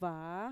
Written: vaa

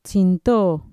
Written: txintoo

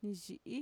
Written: niiyi'i

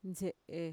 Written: ntxe'e

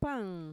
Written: paan

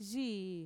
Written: yii

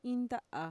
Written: iinnta'à

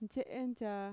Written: ntxe'entxaa

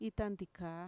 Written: itantikaa

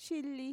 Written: shili

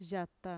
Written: yata

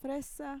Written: fresa

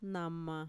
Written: nama

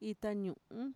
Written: itañu'un

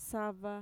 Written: sava

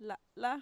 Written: la'la

